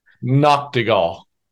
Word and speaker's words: Nachtigal: Nachtigal.